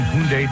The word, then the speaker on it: Hyundai: Get immediate delivery now at any of your Lower Mainland Hyundai dealers.